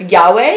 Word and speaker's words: Yahweh: and please don't hate me if I say it wrong, but it's Yahweh. Is that correct? – Yeah.